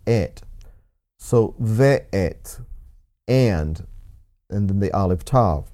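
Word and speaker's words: ve'et (ואת): Again, it says, Breshit bara Elohim Et, there again, Aleph Tav, Hashemayim ve'et ha'aretz. So the second time the word Et or Aleph Tav appears, notice that it's pronounced ve'et, and that's spelled Vav Aleph Tav.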